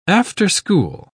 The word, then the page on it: after-school: British English